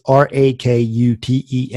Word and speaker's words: Rakuten: It's Rakuten,